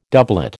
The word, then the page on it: doublet: American English